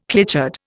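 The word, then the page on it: clichéd: Indian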